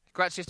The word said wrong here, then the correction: Gracia: Javi Gracia.